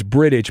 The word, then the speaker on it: Bridich: I think it's Bridich, but he's not British, but it's Bridich.